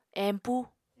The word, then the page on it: empu: Apatani